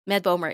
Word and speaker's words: Bomer: Ehm, het zijn twee mannen, gespeeld door Matt Bomer en zeg ik dat goed? Bomer? Ja, ik denk het wel, hè? Het is maar één O namelijk. – Oh ja, geen boomer. Met Bomer. Ja, geen boomer, maar Bomer.